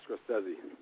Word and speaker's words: Scorsese: And of course, Scorsese, I guess that's the way to pronounce it? – Yeah, Scorsese.